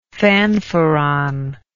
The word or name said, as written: fanfaron